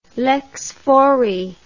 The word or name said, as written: lex fori